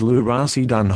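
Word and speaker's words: lurasidone: Luracidone hydrochloride, pronounced Luracidone hydrochloride.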